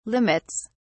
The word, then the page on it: limits: British English